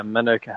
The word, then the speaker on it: Manuka: Now we've been talking about this, but you really are you are a beekeeper, and your business is it Manuka? Is that how you say it? Manuka honey? – Yeah, Manuka honey.